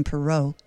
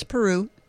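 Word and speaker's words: Peyroux: Madeline Peru, which I've been pronouncing Perot, but it's Peru.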